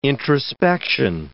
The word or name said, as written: introspection